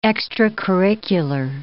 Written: extracurricular

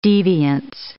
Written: deviance

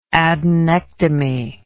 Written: adenectomy

